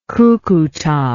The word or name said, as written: Cucuta